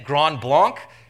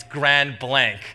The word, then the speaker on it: Grand Blanc: And I was thinking, and I'm from Canada, and so I was thinking, "Is it Grand Blanc?" And they looked at me thinking, you, you, don't know anything. And they had these blank looks on their faces, thinking, no, it's grand blank, right? You can, you can use the English pronunciation for that.